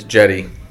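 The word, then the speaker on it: Cedi: It's Jetty. I'm serious. Look it up. That's how you pronounce it. Trust me. I said it. It's not right. It's Jetty. Jetty Osman. – So I said Osman right. – Yeah, but it's not Ceddy. It looks like Ceddy.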